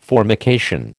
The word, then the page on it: formication: American English